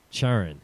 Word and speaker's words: Charon: Charon. – Charon, right? – Yeah. – You know what Charon is, right? – Yeah. – Okay, that's how you say it? Charon? – Yes.